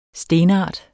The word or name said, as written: stenart